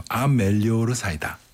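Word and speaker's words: A Melhor Saída: 아멜리오르 사이다. 맞나요? 네. 발음이. – 맞습니다.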